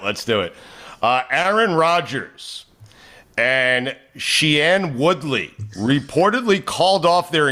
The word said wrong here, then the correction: Shailene: But, well, how do you say it? – Shailene.